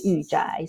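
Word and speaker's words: yu zhai (御宅): when written in Chinese character, pronounced in Chinese as yu zhai.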